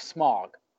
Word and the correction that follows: Smaug: from the Lord of the Rings. – It's pronounced Smaug.